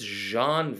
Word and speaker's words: Jean: Jan Vandeveld. That name, that is a good Frenchy name. – I would say, you've said it right about half the time. I think it's Jean Vandeveld.